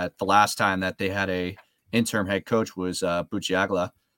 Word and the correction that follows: Bisaccia: if I said his name right. But isn't it like Bisaccia? – Bisaccia.